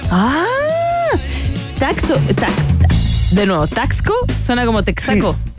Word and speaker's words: Taxco: Eh, digamos, se escribe Taxco, pero acá la gente habla de Tlaxco. Le ponen una L entre el medio, lo pronuncian como Tlaxco